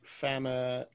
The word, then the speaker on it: Famuyiwa: And where it's Filoni directs episode 5, and I think Rick Fukuyama, if that's how I pronounce his name. – Famuyiwa. Close.